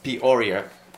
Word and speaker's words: Peoria: Peoria.